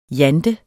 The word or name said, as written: jante